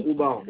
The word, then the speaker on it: Oumar: C'est monsieur Diallo, Oumar. – Ça s'écrit comment, monsieur? – T-I-A-2-L-O, le prénom Oumar, O-U-M-A-R. – Oumar. Oumar, vous m'avez dit. – Oumar, O-U-M-A-R.